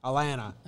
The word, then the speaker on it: Atlanta: You don't pronounce a T. Atlanta.